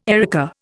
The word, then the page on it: erica: Indian